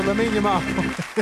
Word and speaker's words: Lamine Yamal: If I said Lamine Yamal, would that sound right to you? – Yeah, that sounds right. – There we go. Lamine Yamal. That's correct.